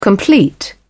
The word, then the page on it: complete: British English